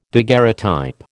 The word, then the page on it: daguerreotype: American English